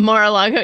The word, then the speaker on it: Mar-a-Lago: There, all the, there's been a court filing with President Trump's case down in Mar-a-Lago. Did I say it right? Did I say Close it right that time? Mar-a-Lago, yeah. Yeah, Mar-a-Lago.